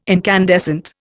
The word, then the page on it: incandescent: Indian